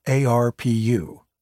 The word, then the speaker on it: ARPU: An important aspect of streaming is something called ARPU, pronounced ARPU. That may sound like a character on The Simpsons, but it means average rate per user.